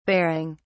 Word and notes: baring: British English

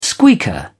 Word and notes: squeaker: British English

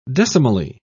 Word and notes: decimally: British English

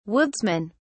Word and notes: woodsmen: British English

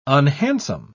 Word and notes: unhandsome: British English

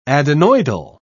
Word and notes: adenoidal: British English

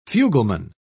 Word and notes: fugleman: British English

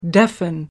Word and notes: deafen: American English

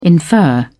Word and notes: infer: British English

